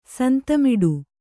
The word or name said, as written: ಸಂತಮಿಡು